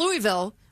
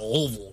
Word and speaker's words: Louisville: Not Louisville. – You got to say it like you have peanut butter stuck in the roof of your mouth. Louisville.